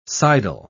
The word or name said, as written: sidle